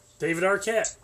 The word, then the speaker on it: David Arquette: David Arquette.